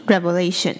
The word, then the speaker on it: revelation: 那 revelation 它的 ve 是发 ve，是短音 revelation，所以这两个字的发音有点不一样。